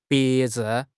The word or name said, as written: 别致